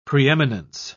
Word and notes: preeminence: British English

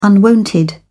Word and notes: unwonted: British English

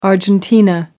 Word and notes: argentina: American English

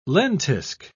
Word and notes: lentisk: British English

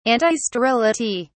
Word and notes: antisterility: British English

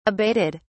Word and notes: abated: British English

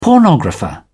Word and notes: pornographer: British English

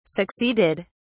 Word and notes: succeeded: British English

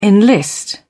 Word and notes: enlist: British English

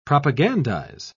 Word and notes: propagandize: British English

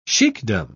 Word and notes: sheikdom: British English

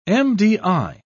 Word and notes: MDI: British English

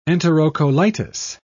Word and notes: enterocolitis: British English

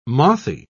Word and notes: mothy: British English

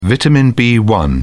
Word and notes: vitamin b1: British English

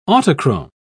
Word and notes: autochrome: British English

